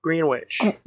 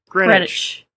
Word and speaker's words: Greenwich: So in Greenwich, Greenwich. Yes, that's how you say it. Greenwich. I forgot to say it like I'm English.